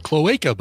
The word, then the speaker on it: cloaca: Cloaca, by the way, I got corrected on the pronunciation.